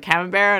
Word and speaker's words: Camembert: And he was like, Camembert? And I was like, oh, that's how you say it. – Here's a good thing for cheese that I can give you a hint. They're mostly French, so just kind of don't say the last bit, and it's close enough.